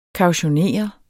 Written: KAUTIONERES

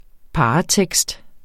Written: paratekst

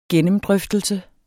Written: gennemdrøftelse

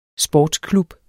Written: sportsklub

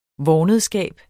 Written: vornedskab

